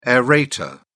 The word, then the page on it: aerator: British English